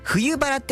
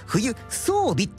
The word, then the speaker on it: 冬装備: で、そのレポーターの人が冬バラって書いて読んだんですよ、初めに。でもあれ、冬装備って読むんですよね。冬バラって書いて漢字で冬装備なんですよね。